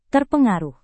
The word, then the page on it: terpengaruh: indonesian